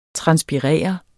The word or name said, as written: transpirere